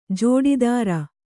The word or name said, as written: ಜೋಡಿದಾರ